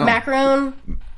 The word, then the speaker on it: McCarron: Does anybody know who AJ, how do you say his last name? – I don't know. – Macron, M-C-C-A-R-R-O-N.